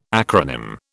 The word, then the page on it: acronym: British English